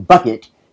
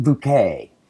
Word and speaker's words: Bucket: Though her last name is spelled B-U-C-K-B-U-C-K-E-T. Bucket, she insists that it's pronounced bouquet.